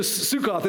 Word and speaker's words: Sukkoth: Some people read this and think Jacob moved to a place that is called Succoth, which is like the King James English version of, never mind, I don't want to... He goes to Sukkoth, is how you pronounce it, right?